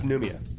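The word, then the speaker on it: Nouméa: It's pronounced Noumea.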